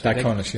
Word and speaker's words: Diaconis: Percy Diaconis. Am I pronouncing it Diaconis? – Diaconis, yeah.